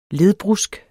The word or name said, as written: ledbrusk